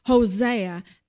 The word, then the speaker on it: Hosea: His name was Hoshea, and it's spelled Hoshea. Now, don't get confused with his name versus the prophet of Israel, Hosea, Hosea.